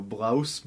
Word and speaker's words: Blose: B-L-O-S-E, so Blouse. – Yo, Blouse. Check that shit out and put it up on the screen. What was his first name, Richard? – Yeah, Richard Blouse. – R-I-C-H-A-R-D-B-L-O-S-E.